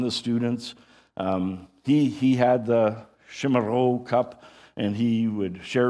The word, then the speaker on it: chimarrão: but this idea of drinking chimarrão. And I'm not saying that right. I can't say it. It's supposed to be a kind of an H, a rolling H at the end, chimarrão, I don't know.